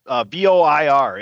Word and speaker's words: Voir: Voir, it's French. – Voir. – We'll go with that. Yeah, there you go. – Voir, like voir, like black noir, but with a V.